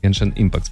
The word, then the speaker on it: Genshin Impact: Но если Genshin Impact... Я правильно произношу название игры? – Genshin Impact, да. – Да? Genshin Impact, спасибо.